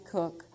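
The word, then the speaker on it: Koch: I retyped some notes of a seminar I went to here Monday night, Kathy Cook. It's spelled K-O-C-H, K-A-T-H-Y, K-O-C-H, if you want to look her up.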